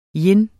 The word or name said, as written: yin